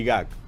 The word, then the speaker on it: GAC: ¿Se pronuncia GAC? – Sí, GAC.